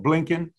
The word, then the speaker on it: Blinken: I'm pronouncing his name wrong. Blinken.